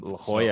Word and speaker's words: La Jolla: Yeah, I'll be out in La Jolla, is that how you say it? Next month. – La Jolla, so, yes, sir.